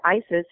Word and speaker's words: Isis: Specifically, I went to the ER and the intake nurse asked me how I pronounced my name. And when I said Isis, she was very sad for me.